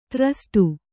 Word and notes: ಟ್ರಸ್ಟು: ṭrasṭu

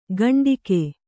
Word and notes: ಘಂಟಿಕೆ: Kannada

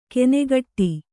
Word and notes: ಕೆನೆಗಟ್ಟಿ: kenegaṭṭi

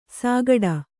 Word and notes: ಸಾಗಡ: sāgaḍa

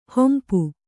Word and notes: ಹಂಪಿ: hampi

